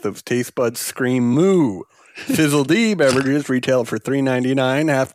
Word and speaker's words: Fizzled: Allow me to present to you my brand new beverage company, Fizzled, actually pronounced Fizzle D, the first ever seltzerized milk.